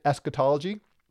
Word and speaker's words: eschatology: Maybe you have a hard time pronouncing it, eschatology.